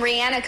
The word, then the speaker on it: Rihanna: Like rhymes with Sienna. Rihanna. – We've got artists like Taylor Swift and Rihanna competing for that award.